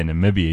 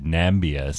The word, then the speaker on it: Namibia: Because you imagine he couldn't say Namibia, he said Nambia. So if he looks at Niger, N-I-G-E-R, oy, can you imagine what'll happen? – Mm-hmm.